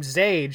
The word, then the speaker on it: Zade: Sheepishly, my mother replied, but, and this is spelled Z-A-D-E. Now, remind me, how is this pronounced? – It is Zade.